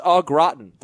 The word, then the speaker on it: au gratin: All beef meatballs, 4 potatoes au gratin.